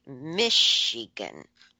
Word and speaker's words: Michigan: We say Michigan. – Ah. – Like, shh. – But that would be S-H. – Yeah. Yeah. I know, it's crazy because it's spelled C-H.